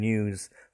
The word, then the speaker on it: news: It's never new for one piece and news for more than one piece. It's the only word you have is news, and pronounce it news. You got that z sound at the end, news.